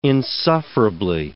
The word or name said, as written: insufferably